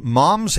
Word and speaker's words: Moms: Sally Burke is president of Moms in Prayer International. Now, I'm saying that the American way because that's how it's spelt, M-O-M-S in Prayer International.